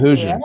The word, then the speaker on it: Hoosiers: So Is it Indiana the Hoosiers? Did I say that right? – The Hoosiers.